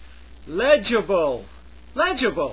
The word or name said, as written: legible